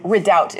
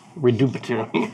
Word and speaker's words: Redoubt: Thanks so much for coming back for our re-debut, whatever it's called when you leave briefly return. – Redoubt is how it's pronounced. – Redoubt. I love it. Redoubt.